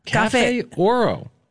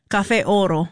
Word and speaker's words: Café Oro: Cafe Oro. – Oh, my gosh, don't say it like that. Café Oro.